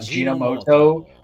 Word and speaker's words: Ajinomoto: A-J-I-N-O-M-O-T-O. – Wow, I butchered that in my head. I thought Ajinomoto, like G-E-N, was right. – Japanese, so there we go. Ajinomoto.